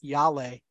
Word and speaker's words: Yale: Real quick, how is it pronounced? Y-A-L-E? – Well, it depends where you're at. I believe in your jurisdiction, it's Yale.